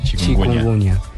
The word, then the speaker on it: chikungunya: minutos Virus chikungunya. – ¿Así se pronuncia? – Sí. – chikungunya.